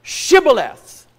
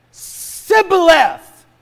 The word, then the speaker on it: Shibboleth: Say the word Shibboleth. And he said, Sibboleth, for he could not frame to pronounce it right.